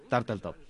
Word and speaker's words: Turteltaub: Dirigeix el John Turteltaub, que coneixem... – Com has dit això? – Turteltaub. No sé si es pronuncia així. – No, ho has dit molt bé.